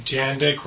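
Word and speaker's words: Jandek: Okay, Jandik rhymes with Jack Chick.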